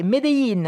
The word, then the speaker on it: Medellín: Bienvenue pour le réveillon à Medellín, prononcé Medellín, hein, avec notre correspondante Najet Benrabah.